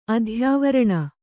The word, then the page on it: ಅಧ್ಯಾವರಣ: Kannada